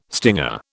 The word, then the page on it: stinger: British English